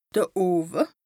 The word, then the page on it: de Owe: Bas-Rhin; Strasbourg